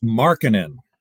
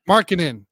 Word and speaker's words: Markkanen: I believe it's Markkanen. – Markkanen. Thank you.